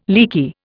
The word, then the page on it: leaky: Indian